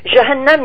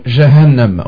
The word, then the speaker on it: Jahannam: Le mot Jahannam, vous qui aimez bien l'arabe, Jahannam, il n'y a aucune difficulté de prononciation. Hein. Jahannam, juste un petit H, un tout petit peu. Jahannam. – Jahannam, ok. – Jahannam, oui, avec deux fois le N.